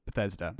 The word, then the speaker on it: Bethesda: Bethesda.